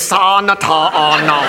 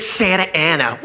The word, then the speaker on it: Santa Ana: Look, I know I didn't finish school, but that frickin' says Sa-na-ta-a-na. They pronounce it Santa Ana.